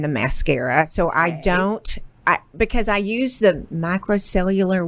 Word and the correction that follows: micellar: water. – The micellar water.